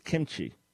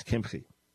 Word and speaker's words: Kimchi: Years ago, when I was a brand new believer, I was reading something about this Rabbi David Kimchi. That's wrong pronunciation. In case you're just about to get off the air, you're pulling up somewhere, that's the wrong pronunciation. It's Kimchi. But I thought it was Kimchi.